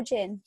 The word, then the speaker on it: Jinn: Jim or Jin? – Jin, like Jin, I think. – As in like the drink? – Yeah.